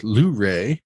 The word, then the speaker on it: Luray: They're based out of Luray, Virginia, or as we say there, Luray. We put the emphasis on the first syllable.